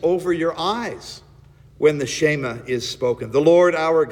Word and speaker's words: Shema: But if you go back to the original language, the actual Hebrew pronunciation is Shema. It's pronounced as one syllable. Shema.